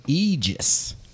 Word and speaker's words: aegis: So I learned a little bit about an Aegis. – Okay. – Sorry, it's Aegis. – Aegis. Okay. – Yes. The E has the long E little thing over it.